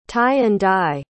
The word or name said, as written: tie-and-dye